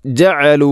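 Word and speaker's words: ജഅലു (جَعَلُوا): ലിതഗ്ഫിറ ലഹും ജഅലു അസ്വാബിഅഹും ജഅലു, ഇവിടെയും ഐനിൻ്റെ ഉച്ചാരണം ശ്രദ്ധിക്കേണ്ടതാണ്. ജഅലു ജഅലു എന്ന രൂപത്തിൽ ആവരുത്, ഹംസയല്ല. ജ ജ ഐൻ തൊണ്ടയുടെ മധ്യഭാഗം.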